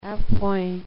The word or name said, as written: a point